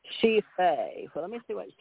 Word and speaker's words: Sidhe Fae: S-I-D-A-T. Okay, this may mean what you're more about. It says... She Fae, S-I-D-H-E-F-A-E, but you say it, D is silent. So S-I-D-H-E is she, like we say hers. She, I, you, he, she, it, we, you, they, she.